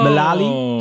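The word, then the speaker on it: El Melali: Het is namelijk Angers-speler Farid El Malili. Oh. – Malali. Sorry, sorry Farid. Verkeerd uitgesproken.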